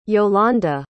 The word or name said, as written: jolonda